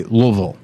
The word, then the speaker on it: Louisville: And he goes, oh, we say Louisville.